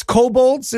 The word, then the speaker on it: kobolds: What do the K-Bads think about ranking abortion versus the Holocaust? And is it the K-Bads? Is that correct? – Yeah, no, it's kobolds is how it's pronounced.